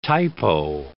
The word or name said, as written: typo